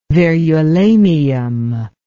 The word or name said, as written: verulamium